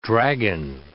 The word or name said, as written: dragon